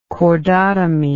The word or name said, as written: cordotomy